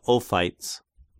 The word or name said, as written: Ophites